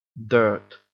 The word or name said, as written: dirt